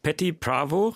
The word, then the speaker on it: Patti Pravo: Patti Pravo, richtig ausgesprochen? – Ja.